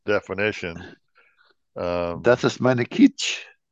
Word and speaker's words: kitsch: Another word is kitsch. I, I don't know if I'm saying it right. It's K I T S C H kitsch. – Kitsch. – Kitsch. – Like kitchen. Kitch. Only it's kitsch.